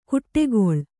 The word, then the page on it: ಕುಟ್ಟೆಗೊಳ್: Kannada; kuṭṭegoḷ